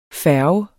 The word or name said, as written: færge